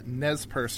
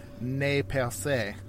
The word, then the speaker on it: Nez Perce: Now, Lee, John Griswold's wife was a member of the Nez Perce tribe.